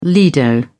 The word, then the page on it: lido: British English